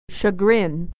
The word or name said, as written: chagrin